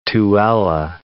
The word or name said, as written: Tooele